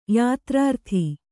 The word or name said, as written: ಯಾತ್ರಾರ್ಥಿ